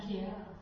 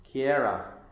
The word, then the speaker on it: Chiara: How do you pronounce her name? C-H-I-A-R-A? – Kiara. – Kiara. Okay. Kiara.